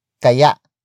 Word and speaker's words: kaya: The next word is kaya. Kaya. Kaya. Kaya is spelled as K-A-Y-A. Note the glottal stop at the end.